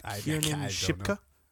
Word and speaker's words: Kiernan Shipka: Fucking, how do you even pronounce this shit? – Kiernan Shipka? There you go.